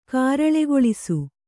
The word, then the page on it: ಕಾರಳೆಗೊಳಿಸು: kāraḷegoḷisu